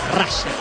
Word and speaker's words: Russell: No, pronunciación la real es Russell. – Russell. – Sí. Muchos le dicen Russell, está perfecto, pero la pronunciación correcta es Russell.